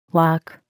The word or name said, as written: wok